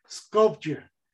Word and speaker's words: Skopje: This country, at least it's specified as a country, their capital city is pronounced Skopje.